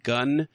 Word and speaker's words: Gwnn: It's called Gunn, and it's spelled G-W-N-N, and it kind of stands for the girl with no name.